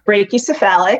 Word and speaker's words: brachycephalic: I think it's brachycephalic. – Brachycephalic. – Ooh, there we go.